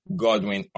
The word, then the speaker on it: Godwin: And my name is Godwin Orkeh. And it's a, what? Godwin? Goodwin? And I said, come on. You can pronounce Godwin. It should not be that complicated. At least you can say Godwin.